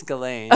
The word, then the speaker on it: Ghislaine: Do I Ghislaine. Say her name wrong? – I think it's Ghislaine.